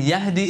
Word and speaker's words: يَهِدِّي: أَفَمَنْ يَهْدِي إِلَى الْحَقِّ أَحَقُّ أَنْ يُتَّبَعْ أَمَّنْ لَا يَهِدِّي not يَهْدِي يَهِدِّي إِلَّا أَنْ يُهْدَى.